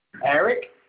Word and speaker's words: Eric: Eric, E-R-I-C.